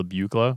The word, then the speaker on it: Buchla: I think it's pronounced Buchla. – I recognize that. – Buchla.